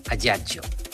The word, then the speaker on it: Ajaccio: Bueno, ellos lo pronuncian, ¿qué tal, antes de que nada? Lo pronuncian Ajaccio.